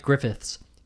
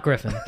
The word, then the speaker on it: Griffiths: Every time I see it, I'm like, Griffiths. Griffiths. Not Griffin. Not Griffin.